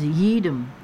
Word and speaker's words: YEEDM: She often books local DJs who specialize in the crossover between country and electronic dance music, popularly known as Yeedem, Y-E-E-D-M, pronounced to rhyme with Yeedem.